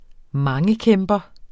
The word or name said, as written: mangekæmper